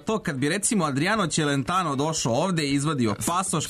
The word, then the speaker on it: Celentano: Celentano jeste, izgovara se Celentano, ali smo rekli Underworld nije Underworld,